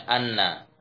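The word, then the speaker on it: anna (أنّ): Kalau cara bacanya anna atau inna tinggal melihat hamzahnya. Kalau hamzahnya dibaca di bawah berarti bacanya inna. Kalau hamzahnya di atas berarti bacanya anna. Ya kan? Ya, seperti awa akhwatu inna. Hamzahnya di bawah dibaca inna. Hiya hamzahnya di atas dibaca anna.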